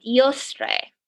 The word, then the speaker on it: Eostre: He's a British monk, and he says that it's related to a pre-Christian goddess named in England named Eostre. I could have just butchered that. It is E-O-S-T-R-E.